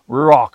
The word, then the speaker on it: Ruach: It's spelled R-U-A-C-H. It's a hard C-H. It's almost like a cough K. Ruach.